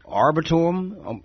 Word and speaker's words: Arboretum: I'm wondering, am I pronouncing this right? The UCF Arboretum? – Arboretum. – Oh, wow. I was way off.